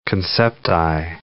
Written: concepti

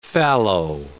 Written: fallow

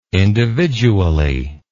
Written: individually

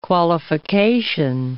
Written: qualification

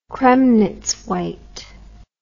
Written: Kremnitz white

